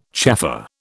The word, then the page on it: chaffer: British English